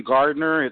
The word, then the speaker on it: Gardner: It's uh, Badru Akeem Gardner.